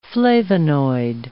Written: flavonoid

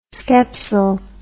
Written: skepsel

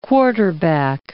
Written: quarterback

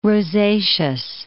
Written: rosaceous

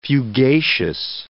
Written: fugacious

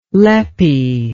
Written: leppy

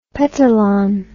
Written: petalon

